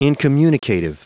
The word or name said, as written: incommunicative